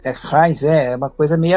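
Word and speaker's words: Frijns: é a pronúncia é Frains. – Frains? – Lá, é, Frains, é.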